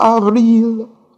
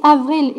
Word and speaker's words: avril: Il est paraît-il des terres brûlées, donnant plus de blé qu'en meilleur avril. Et combien le soin. – Stop you. You see, avril, it's really the emphasis on the A also. Avril.